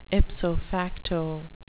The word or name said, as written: ipso facto